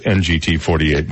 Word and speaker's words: NGT48: It's pronounced NGT 48, by the way.